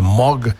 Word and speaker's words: Moog: Bueno, The Moog, realmente se pronuncia Moog.